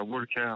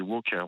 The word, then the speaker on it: Walker: À propos de Walker, alors on dit pas Walker, on dit Walker, on prononce pas le L, hein, je vous précise.